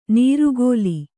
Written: ನೀರುಗೋಲಿ

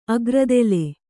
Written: ಅಗ್ರದೆಲೆ

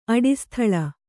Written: ಅಡಿಸ್ಥಳ

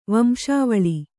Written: ವಂಶಾವಳಿ

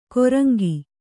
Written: ಕೊರಂಗಿ

